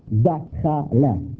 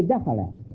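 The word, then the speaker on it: dakhala (دخل): The Arabs pronounce, entered like went in that door, dakhala, dakhala, dakhala. They say dakhala, you see?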